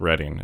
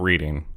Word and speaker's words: Reading: So coming to Pennsylvania, I was like, oh, okay, it's pronounced Reading. It's not Reading. – Mm-hmm. – It's just Reading.